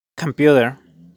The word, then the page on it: computer: /kəmˈpjutər/